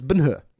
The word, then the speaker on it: Ben-Hur: One thing I will point out about this film is that we're pronouncing it wrong. – Ah. – Because about 70 minutes into the film, they say his name for the first time, and it's Ben Hur. It's like Ben Hur.